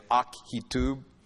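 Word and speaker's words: Ahitub: By the way, just to throw this out, in the Hebrew language, when an A and an H are together, there's a K sound in there. That's why I'm pronouncing it Akitub.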